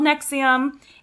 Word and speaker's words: NXIVM: But it's called Nexium. It's spelled like N-X-I-V-M.